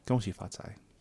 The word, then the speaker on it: Gong Xi Fa Cai: Gong xi fa cai. – How? – Gong hei fa choy is Cantonese.